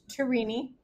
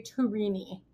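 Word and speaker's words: Tourigny: And so I looked up the Ottawa 67's pronunciation guide, and it is Andre Tourini. Tour, like the Tour de France. E, like E, it's a spider. And knee, like the body part.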